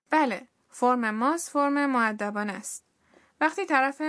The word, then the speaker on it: ます: مصوت او در سوی فعل ماسو بی صداست و این فعل به شکل ماس تلفظ میشه.